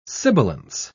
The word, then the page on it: sibilance: British English